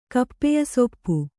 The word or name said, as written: ಕಪ್ಪೆಯಸೊಪ್ಪು